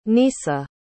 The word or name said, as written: nisa